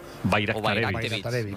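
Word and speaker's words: Bajraktarević: Bayraktarevich. O sea, la J la conviertes en una I, Bayraktarevich.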